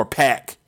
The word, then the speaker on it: Pac: Or Pac is the correct pronunciation.